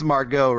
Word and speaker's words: Margot: I think it's Margot Robbie. – Is it? So the T shouldn't be there. – So it's a silent T.